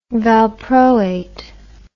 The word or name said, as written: valproate